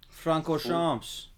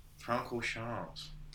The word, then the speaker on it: Francorchamps: Francorchamps. – Francorchamps.